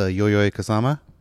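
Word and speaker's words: Yayoi Kusama: Shout outs to Yoyoi Kusama.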